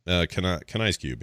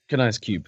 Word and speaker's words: Kanai's Cube: How do you say it? – Kanai's cube. Yeah. – Kanai's cube.